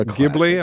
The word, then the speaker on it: Ghibli: Ghibli.